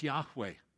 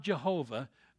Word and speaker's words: Yahweh: God's name is Yahweh. It's not Jehovah, although the German influence on the English language has caused us to pronounce it that way.